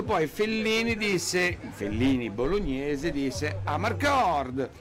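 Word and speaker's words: Amarcord: Però in realtà la pronuncia esatta sarebbe Marcord, mi ricordo, no, di quella cosa.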